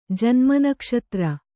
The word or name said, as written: ಜನ್ಮನಕ್ಷತ್ರ